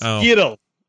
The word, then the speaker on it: Yrel: It's Yrel. Pronounced by Bo. – It's Yiddle. That's how they say it in the game.